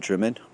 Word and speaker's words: Drymen: By 2 o'clock we were in Drimmen, which is spelt D-R-Y-M-E-N, dry men.